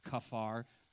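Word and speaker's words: kaphar: Kafar is the Hebrew word to cover. It almost sounds like the word cover.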